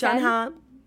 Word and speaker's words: Jon Hamm: Hamm? Is that what I said? – John Hamm. Yeah, John you just keep him like kind of a British. – That's how he pronounces it.